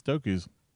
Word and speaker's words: Toku: T-O-K-U apostrophe S. – Yeah, it's Toku's. I don't know how, you pronounce it. It sounds like it, like Roku, right? – Kind of, yeah. But it's T-O-K-U and then apostrophe S, Toku's.